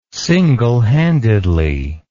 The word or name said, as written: singlehandedly